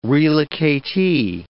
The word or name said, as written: relocatee